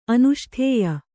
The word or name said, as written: ಅನುಷ್ಠೇಯ